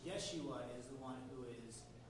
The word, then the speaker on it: Yeshua: So the Hebrew word, how you pronounce it in Hebrew, is Yeshua.